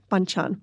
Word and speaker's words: banchan: Let's talk a bit about, how do I pronounce this? Banchan? Banchan. – Perfect.